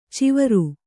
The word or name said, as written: ಚವರ